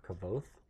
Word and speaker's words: Kvothe: Main character from a book series called The King Killer Chronicles, which is Kvothe. – The Kinky Killer Chronicles? – Yes, The Kinky Killer Chronicles. – Kvothe? – Kvothe. – Kvothe? – Kvothe, yeah.